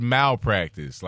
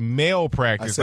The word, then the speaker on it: malpractice: You said malpractice, how it's correctly pronounced. – Right. – Jay said mail practice.